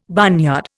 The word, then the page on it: barnyard: Indian